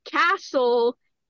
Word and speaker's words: castle: And it was just like, just so you know, castle is kind of a rhyme with asshole.